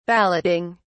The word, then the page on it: balloting: British English